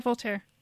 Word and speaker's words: Voltaire: God knows I loved high school philosophy in French, and I can tell you about Voltaire's Candide and why Voltaire, am I saying that right? – Yeah, Voltaire.